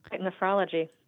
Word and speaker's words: nephrology: You said it exactly right, nephrology.